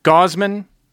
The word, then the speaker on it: Gausman: Gausman almost rhymes with Bozeman. – Sure. – Which is a town in Montana.